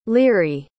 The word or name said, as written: laoghaire